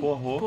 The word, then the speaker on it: Poirot: O filme é, é dirigido pelo Kenneth Branagh, que também é o personagem principal, o Hercule Poirot Hercule, Hercule Poirot.